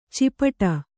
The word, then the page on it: ಚಿಪಟ: cipaṭa